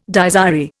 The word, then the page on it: Dies irae: Indian